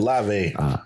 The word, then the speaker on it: Olave: I can't say his name, but Olave.